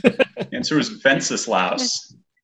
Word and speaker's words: Wenceslaus: A good King Wenceslaus looked down on the feast of Stephen. – I learned something today. – I learned how to pronounce Wenceslaus.